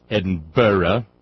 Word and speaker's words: Edinburgh: You will end your love affair with the letter Z, pronounced Z, not Z, and the suffix I-Z-E will be replaced by the suffix I-S-E. You will learn that the suffix bur, B-U-R-G-H, is pronounced burra, e ergo gratias Edinburgh, lesson I had to learn recently.